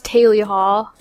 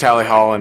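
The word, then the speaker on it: Tally Hall: And Mark Williams, the Mark Williams, just tweeted me and was like, apparently it's pronounced Taily Hall, but... – I can speak to that. That's a very uncommon misconception. It's always been pronounced Taily Hall, and seems that we've never encountered that problem before.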